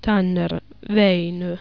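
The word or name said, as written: Tanner, Väinö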